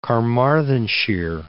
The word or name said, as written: Carmarthenshire